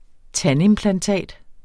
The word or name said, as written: tandimplantat